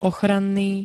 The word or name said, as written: ochranný